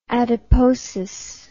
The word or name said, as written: adiposis